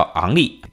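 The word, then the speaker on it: Henry: 就比如说我们这个足球水平接近于咱们国家礼仪大帝的这个亨利，法国足球运动员亨利啊，其实他是叫昂利，有点像昂利一号的这个昂利啊，h 不发音。你想，你不发音，你写上去干嘛？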